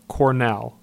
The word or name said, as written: Cornell